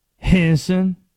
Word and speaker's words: Henderson: There's a town 20 minutes from Youngsville, North Carolina, my hometown, called Henderson, North Carolina, spelled just like that, Henderson. You want to know how the people in Henderson pronounce it? Henson. Two syllables. Henson. Appreciate the call there.